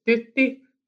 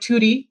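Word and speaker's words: Tytti: Yeah, so my name in Finnish would be pronounced Thuti Tuulinen. And it's difficult in many ways. Like in the US, the way it's spelled, it's T-Y-T-T-I. And when you see that name in front of your face without knowing that you can pronounce it, which like in like Thuti, which became my nickname, many people would rather take a stab and say titty.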